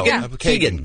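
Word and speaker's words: Keoghan: And we have Phil Keegan. Is that how you say his name? Amazing Race? – Host? Amazing Race, dude. – I believe so. – Kagan.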